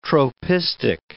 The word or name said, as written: tropistic